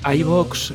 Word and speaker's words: Ivoox: Y además, ojo, porque la primera pregunta es cómo tenemos que pronunciar Evox. ¿eh? O sea que dejamos aquí el misterio para que escuchéis la, respuesta, porque si es Evox, Evox... – iVox, es que ahí, tiene, tiene mucho intríngulis esto.